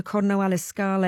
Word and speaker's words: Corno alle Scale: stage 9 to Corno alle Scale.